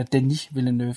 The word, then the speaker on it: Denis Villeneuve: Nej, øh, det er Rival af Dennis Villeneuve, eller Denis Villeneuve.